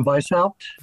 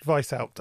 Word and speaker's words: Weishaupt: because I've Adam Weishaupt. – Weishaupt, okay, right, because I've heard about five different pronunciations of that name.